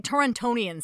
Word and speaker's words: Torontonian: Like, Torontonian sounds stupid to me. I don't know. Especially because you have to pronounce the second T.